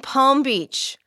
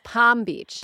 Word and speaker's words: Palm Beach: They pronounce Palm Beach. – Palm Beach. – They don't like. Do they not? Palm. Do they like make you surrender the L before you arrive in Florida?